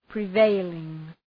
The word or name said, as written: prevailing